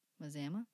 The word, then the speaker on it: Mazama: You know, manasma, mazama. There's no N there. You just like threw an N at the before the Z there.